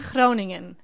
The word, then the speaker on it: Groningen: Now you're from a place in the Netherlands called Groningen. – Groningen.